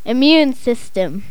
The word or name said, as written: immune system